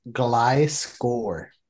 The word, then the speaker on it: Gliscor: Yeah, just to let you know, I googled this and it's pronounced Gliscor. – Yeah, because it's a gliding scorpion.